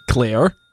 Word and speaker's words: Claire: wrote in, regarding your theories about Jamie's motivation in the peacup scene after Callier, is that how he would say it? Clear? How would you say it? – Claire. – What? No, he wrote C-A-L-I-R.